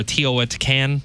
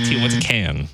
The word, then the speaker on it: Teotihuacan: Is it Mexico City, Tijuana, Guadalajara, or Teotihuacan? I'm saying that so wrong. – Teotihuacan. – You were wrong on that one. It is actually Teotihuacan.